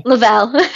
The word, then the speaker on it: Lovell: Lovell.